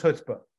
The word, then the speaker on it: chutzpah: I think of it, and actually even chutzpah, people drop the C frequently, kind of like a stop sign in Toronto. You don't really need to stop. You can go through. It's the same with the C. You don't need to pronounce the C. It's just chutzpah.